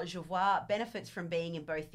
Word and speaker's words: Jervois: So, Jervois is the way Jervois. We pronounce it.